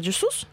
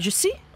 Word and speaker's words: je suis: Простите, у меня есть ощущение, что в новостях это произносилось как «Джесус». Переводчик с французского тебе говорит, что нужно говорить «je suis».